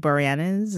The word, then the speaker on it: Boreanaz: And that show stars none other than David Boreanaz.